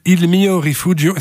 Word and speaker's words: Il mio rifugio: Alors le morceau qu'on va écouter maintenant s'appelle Il mio rifugio.